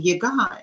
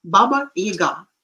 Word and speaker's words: Baba Yaga: We call him Yaga, and somehow it is the stress is falling on the first syllable, which is wrong. Baba Yaga.